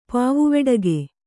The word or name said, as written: ಪಾವುವೆಡಗೆ